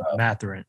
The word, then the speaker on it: Mathurin: Oh, Benedict Mathurin? – Mathurin.